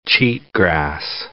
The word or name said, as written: cheatgrass